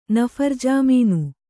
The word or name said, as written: ನಫರ್‍ಜಾಮೀನು